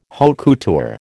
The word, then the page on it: haute couture: American English